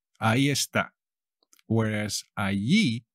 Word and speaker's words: ahí: Ahí, tío. – Allí. – Now, there is also ahí. The H is never pronounced.